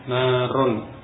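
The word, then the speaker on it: narun (نَارٌ): Walaupun tulisannya mungkin sama, tapi ini fi'il maka dibaca naro. Kalau isim narun api atau neraka itu narun, tulisannya sama. Tapi jenis katanya berbeda, yang satu isim yang fi'il, membacanya juga berbeda ya. Narun dengan tanwin dhamma, adapun naro ini fi'il, huruf akhirnya fathah, fi'il madhi.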